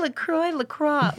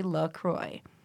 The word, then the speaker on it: LaCroix: It is, and a lot of people don't know the pronunciation, and I think it's actually LaCroix.